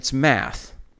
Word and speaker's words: math: And we would pronounce it the same way as, you know, the abbreviation for mathematics. It's math.